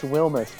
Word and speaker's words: Wilmes: Do we know? Is there a proper way to say it? – it's Wilmes, but I almost feel weird. Yeah, say it however you want. Honestly, I don't care.